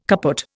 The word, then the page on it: kaput: Indian